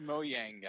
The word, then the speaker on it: Mojang: Apparently it's pronounced Mojang, guys.